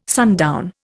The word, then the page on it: sundown: Indian